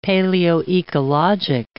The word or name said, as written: paleoecologic